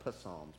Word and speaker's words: Psalms: And it looks like Psalms, but it's really Psalms. It's a silent P there.